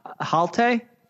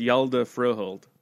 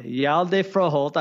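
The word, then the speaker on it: Hjalte Froholdt: And I had already, how do you pronounce the guy's name? Halte? – Yalda. – Yalda. – I believe it's Yalda Froholt. – Okay. Yalda Froholt.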